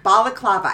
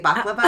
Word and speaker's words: balaclava: It's a balaclava. I think I said it correctly. – Oh, yeah, yeah. – Instead of baklava. Yeah, yeah, yeah. – I typically say baklava, and that's some sort of dessert.